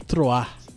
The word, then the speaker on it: Troyes: O PSG meteu 9 a 0 Ó, ó a pronúncia agora, no Troar que escreve Troyes.